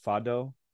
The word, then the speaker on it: fado: have you ever heard the Portuguese, uh, I think it's pronounced fado?